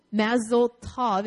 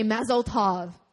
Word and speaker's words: mazel tov: Okay, do you know mazel tov? Is that how you say it? Mazel tov? Pearl, is that right? You're the closest to a Jewish person that I know. Tov, yes.